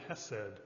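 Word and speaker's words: hesed (חסד): The Hebrew word is hesed, by the way, or the Hebrews uh, kind of say a KH together. Hesed is how they say it.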